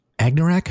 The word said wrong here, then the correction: Anorak: How do you say it? – Anorak, I thought. – Anorak. Yeah.